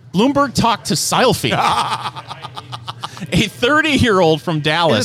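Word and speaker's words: Silfy: Sylphie. – Can you spell that? – S-I-L, now not P-H-Y, it's S-I-L-F-Y.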